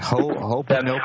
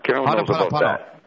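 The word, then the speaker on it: Ho'oponopono (Hoʻoponopono): Mel in Toronto, Would comment on, I don't know how to say this, Ho'oponopono. Ho'oponopono.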